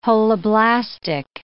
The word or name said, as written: holoblastic